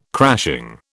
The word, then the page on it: crashing: British English